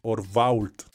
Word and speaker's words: Orvault: Orvault, no que nunca pronunciar bien, es Orvault.